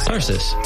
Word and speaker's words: Tarsus: Tarsus.